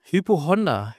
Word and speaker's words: Hypochonder: Was ist mit Hypochonda? – Hypochonder. Hypochonder heißt das. Hypochonder.